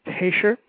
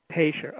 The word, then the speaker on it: Taicher: And I'm sorry, I mispronounced your name earlier. It's Taicher. – That's right. – Taicher.